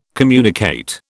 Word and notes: communicate: British English

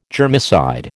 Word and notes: germicide: American English

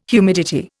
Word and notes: humidity: Indian

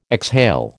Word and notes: exhale: American English

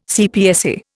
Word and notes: CPSA: Indian

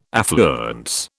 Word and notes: affluence: British English

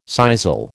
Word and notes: sisal: American English

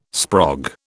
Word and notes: sprog: British English

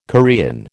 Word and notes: Korean: American English

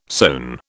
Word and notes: sewn: British English

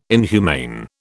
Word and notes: inhumane: British English

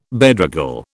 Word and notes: bedraggle: British English

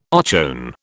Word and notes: ochone: British English

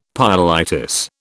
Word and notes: pyelitis: British English

